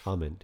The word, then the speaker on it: Ament: Ament? I'm not even sure how to pronounce his name. I assume Ament. – Ament, yeah. – Ament. – A-M-E-N-T.